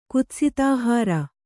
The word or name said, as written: ಕುತ್ಸಿತಾಹಾರ